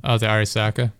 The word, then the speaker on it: Arisaka: I don't know how to properly pronounce it. I called it the Ariska just because it was the Arisaka.